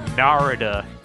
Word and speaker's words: Narada: I'm looking up Norada Michael Walden on Wikipedia, and it says his name is pronounced Narada.